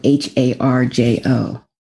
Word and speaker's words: Harjo: Her last name is spelled H A R J O, Harjo.